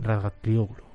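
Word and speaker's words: Razgatlıoğlu: La primera carrera en Estoril se la ha llevado Tlioglu ¿lo he dicho bien? – Rasga Tlioglu Muy bien.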